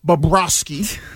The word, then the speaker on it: Bobrovsky: So as far as the Florida Panthers, the free agents, the goalie, Sergei Bobrovsky, is going to be a free agent.